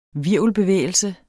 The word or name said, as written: hvirvelbevægelse